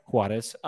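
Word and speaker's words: Juárez: Is it Juarez? Is that how you pronounce that? – Juarez.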